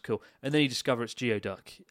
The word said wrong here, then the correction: geoduck: Uh, sorry, Gooey Duck.